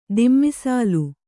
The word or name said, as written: ದುಮ್ಮಿಸಾಲೆ